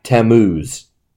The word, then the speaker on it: Tammuz: So the third one, he finds these women weeping for this Babylonian god, Tammuz. How do you feel about that pronunciation? – Sounds good to me.